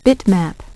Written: bitmap

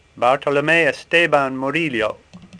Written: Murillo

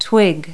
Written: twig

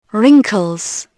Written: wrinkles

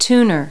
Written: tuner

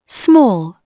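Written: small

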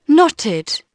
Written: knotted

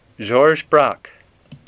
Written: Braque

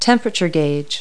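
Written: temperature gauge

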